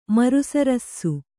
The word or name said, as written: ಮರುಸರಸ್ಸು